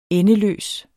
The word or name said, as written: endeløs